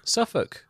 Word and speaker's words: Suffolk: Suffolk.